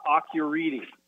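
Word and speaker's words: Akureyri: So it's Acureyri. R-I, and so they're pronounced as Acureedy.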